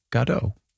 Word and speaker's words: Godot: But we did read that Beckett himself said Waiting for Godot.